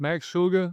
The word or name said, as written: Max Shulga